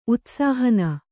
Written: ಉತ್ಸಾಹನ

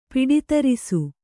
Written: ಪಿಡಿತರಿಸು

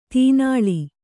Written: ತೀನಾಳಿ

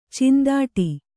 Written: ಚಿಂದಾಟಿ

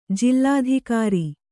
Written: ಜಿಲ್ಲಾಧಿಕಾರಿ

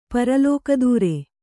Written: ಪರಲೋಕದೂರ

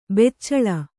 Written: ಬೆಚ್ಚಳ

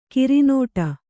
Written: ಕರ್ನಾಟ

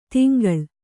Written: ತೆಂಕಳ

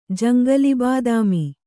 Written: ಜಂಗಲಿಬಾದಾಮಿ

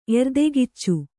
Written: ಎರ್ದೆಗೆಚ್ಚು

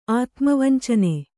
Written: ಆತ್ಮವಂಚನೆ

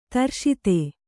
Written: ದೃಷದ

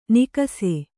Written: ನಿಕಸೆ

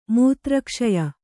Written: ಮೂತ್ರಕ್ಷಯ

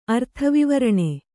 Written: ಅರ್ಥವಿವರಣೆ

